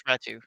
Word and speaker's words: Ratu: It looks like Ratti, like, but it's Ratu.